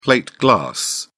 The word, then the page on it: plate glass: British English